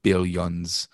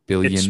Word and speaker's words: billions: billions of dollars. – Billions. It's pronounced billions. – Billions. I always make it more the O instead of the I for some reason.